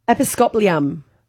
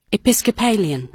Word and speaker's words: Episcopalian: Episcopalium. Lian. – Okay. – Episcopalian. – Episcopalian. This is the actual pronunciation? – Episcopalian.